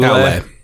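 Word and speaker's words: Aulë: He is, of course, as you mentioned, he's aligned with the smith Ule. Is that how you pronounce it? – Ule? – Aule. – Aule.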